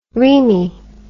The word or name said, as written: reme